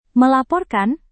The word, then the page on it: melaporkan: Indonesian